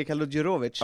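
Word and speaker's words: Kaludjerović: Vasilij Kaludjurovic. Ja. – Mm. Fan, det är bra uttal på det där.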